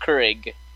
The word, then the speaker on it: Keurig: Is it Keurig? – I would say more Keurig. – Keurig? – No, Keurig. Like, not as much of an E or egg, just Keurig.